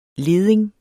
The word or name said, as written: leding